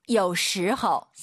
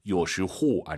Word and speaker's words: 有时候: 有时候. – 삼성이성 아아 들려야 됩니다. 요시호 아니죠. 오유는 어우 발음이에요. 허우예요. 요시호 꼭 기억하세요.